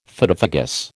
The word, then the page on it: Phytophagous: American English